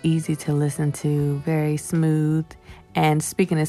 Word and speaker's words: Cmooth: That is my good friend, goes by the name of Smooth, and that's spelled C-M-O-O-T-H, but pronounced Smooth.